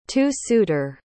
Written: two-suiter

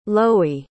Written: lowy

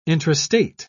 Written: intrastate

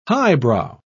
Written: highbrow

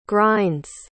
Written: grinds

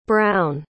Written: browne

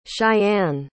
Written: chayanne

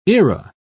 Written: era

